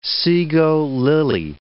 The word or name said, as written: sego lily